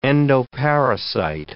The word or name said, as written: endoparasite